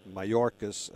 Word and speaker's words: Mayorkas: The Biden administration, the group that they've put together under Alex Mayorkas. I think that's how you say his name. – It is, yes.